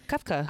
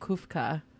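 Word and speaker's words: kefta: Is it, was I saying it wrong? – Kefka? – Because it was the kebabs? – Yeah, kefka. – I was calling it kufka.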